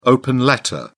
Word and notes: open letter: British English

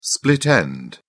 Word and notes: split end: British English

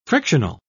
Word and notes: frictional: British English

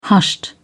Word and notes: hushed: British English

